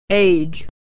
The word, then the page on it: age: ey